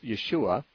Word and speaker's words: Yeshua: The Hebrew pronunciation of Jesus' name was Yeshua, but when translated into Greek, the Greek language does not have a SH sound in it. There's just no way to make an SH sound in the Greek alphabet.